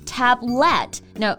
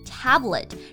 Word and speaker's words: tablet: 首先我们来看一下它的发音，很多同学呢会读成 tablet，no，它的重音在第一个音节。 tablet，然后字母 e 在这里是发一个唰的音，对，tablet。